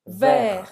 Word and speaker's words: vert: Vert. Ver. Você não vai pronunciar esse T. Vert.